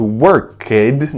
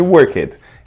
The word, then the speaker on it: worked: Observe a pronúncia do worked, não existe worked. É worked, aula de pronúncia, worked.